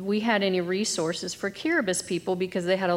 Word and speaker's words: Kiribati: The Samoan trip, which I have a video I'll show next, but always loves talking about the trip to Kiribati. – So Kiribati. It's spelled Kiribati, but yes, it's pronounced Kiribati, and it wasn't even on our list to go there.